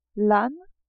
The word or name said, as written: ane